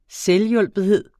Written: installationsprogram

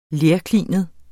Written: lerklinet

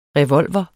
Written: revolver

